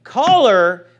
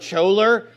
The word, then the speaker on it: choler: Or if you want to pronounce it like James White, it's choler. Okay. But he also pronounces a do a doe, like doe a deer, a female deer. So basically, this word is supposedly an archaic word, choler. Well, no, it's just not pronounced choler. It's choler.